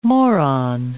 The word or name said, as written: moron